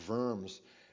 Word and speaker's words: Worms: But today is that 500th anniversary of the Diet of Worms, or Worms as it's to be pronounced.